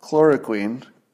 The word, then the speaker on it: chloroquine: Chloro chloroquine. Chloroquine.